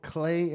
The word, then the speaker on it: Klei: Clay Entertainment, K-L-E-I Entertainment. Okay. It rhymes with play.